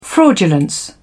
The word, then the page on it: fraudulence: British English